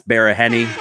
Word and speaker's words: Baraheni: Baraheni. Did I say that right, Baraheni? – Yeah, you did.